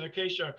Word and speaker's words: acacia: And they call it, I call it acacia, and I could, because that's the right pronunciation, acacia,